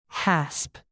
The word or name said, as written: hasp